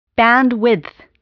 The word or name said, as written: bandwidth